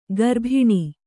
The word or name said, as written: ಕರಬೋನ